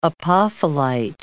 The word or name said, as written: apophyllite